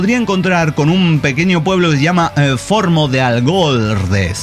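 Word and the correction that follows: Fornos de Algodres: Bueno, Formo de Algordes. – Fornos. – Formos de Algordes.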